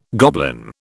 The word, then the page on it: goblin: British English